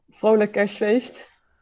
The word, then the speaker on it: Vrolijk Kerstfeest: Vrolijk Kerstfeest.